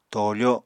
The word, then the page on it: toofo: Apatani